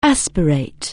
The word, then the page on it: aspirate: anglais